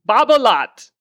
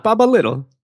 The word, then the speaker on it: Babolat: Babolat. Not Babolat. Not Baba Little. Babolat.